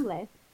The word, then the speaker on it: Humble: It's pronounced Humble. – Humble. Humble. – The H is silent. – Thanks. Yeah. – I thought it was Umble. – Umble? Umble, Texas.